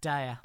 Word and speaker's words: Daya: It's pronounced Daya.